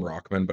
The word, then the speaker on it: Rahman: Haseem Rahman Jr. They call him Rockman, but it's not how it's spelled, and I refuse to say that.